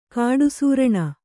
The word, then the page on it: ಕಾಡಸೂರಣ: kāḍasūraṇa